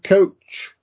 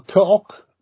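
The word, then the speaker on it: Koch: Uh, my my German's not as good as Stuart's, um, but uh, I understand coach or cock, whichever he, how it's pronounced. It's uh, the latter, yeah.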